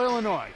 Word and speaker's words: Illinois: Spell Illinois, Tanner. – Did you know there's an N in there? – I did. And a silent S.